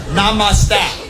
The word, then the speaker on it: Namaste: Monostat, right? What do they say? Monoste. Namaste. What is monostat? Did I say that wrong? Namaste, right? Namaste.